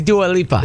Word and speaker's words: Dua Lipa: We are a radio station. It is Dua Lipa.